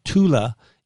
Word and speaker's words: Thule: That's Thulin spelled T-H-U-L-I-N. That's where the name probably comes from. But Thule is how you pronounce the name.